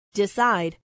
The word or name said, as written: decide